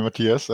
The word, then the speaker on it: Matthias: Joining us from Hanover in Germany is Matthias, I butchered that, sorry, a.k.a. Sad Toaster, Mr. Toast, Toast or Toaster. Matthias, what should we be calling you for the duration? – Hello, you can call me Matthias, and you did fine for an English guy anyway.